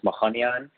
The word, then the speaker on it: Macanian: It's an Iranian name that's actually pronounced Mahanian, but Western people have a hard time with that. So you could just say it however you want.